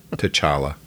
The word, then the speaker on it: T'Challa: You can't spend an episode and a half on Tony Stark's drinking problem before he faces T'Challa. I'm mispronouncing his name. Anyways, in Captain America Civil War. T'Challa.